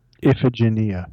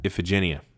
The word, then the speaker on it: Iphigenia: He's again manipulated by his mother and the gods, whom Agamemnon has secured the guarantee of victory from by killing Iphigenia. I can never say her name right. – Iphigenia. – Iphigenia. Sorry. The Latinist in me wants to make it all a hard G.